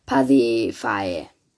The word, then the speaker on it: Pasiphae: Zu meiner Familie gehören mein Vater Minos, der König von Kreta, und meine Mutter Pasiphae. Äh, nein, wie spricht man das aus? – Pasiphae.